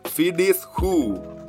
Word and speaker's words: Phyllis Ho: 好，感谢这位 Phyllis Ho，因为。呃，有时候大家的英文名字真的是不知道怎么念，我都会去 Google 一下。那这个 Google 是念 Phyllis Who，所以就有点念错了，不好意思。